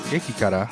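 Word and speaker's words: Geki Kara (激辛): It's another one called like Geki Katarun or something. – Geki Kara.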